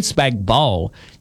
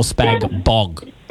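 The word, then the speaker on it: spag bol: Kim, Ian Bell, Post Hill, is it spag bowl or spag bog? – Spag bowl, the G is silent.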